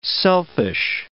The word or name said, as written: selfish